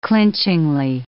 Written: clinchingly

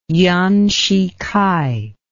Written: yuan shih kai

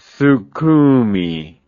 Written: sukhumi

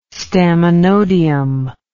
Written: staminodium

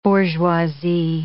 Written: bourgeoisie

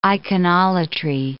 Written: iconolatry

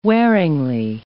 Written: wearingly

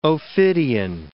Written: ophidian

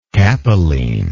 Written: capeline

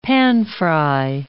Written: panfry

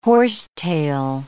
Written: horsetail

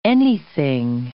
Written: anything

